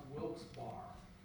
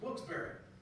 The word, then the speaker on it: Wilkes-Barre: See, some of you have seen the name and you think it's pronounced Wilkes-Barre. No, it's Wilkes-Barre. Wilkes-Barre, Pennsylvania.